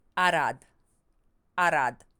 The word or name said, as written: Arad